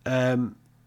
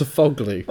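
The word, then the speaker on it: Sofuoğlu: Sofalaglu? Is that how you say it? – That is definitely not how you say it. You've put Sofalaglu. It's not sofa, it's just Sofoglu.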